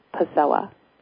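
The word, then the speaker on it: Kposowa: It's spelled with a K, K-P-O-S-O-W-A. It's my family's last name, and the K is silent, so it's pronounced Posowa.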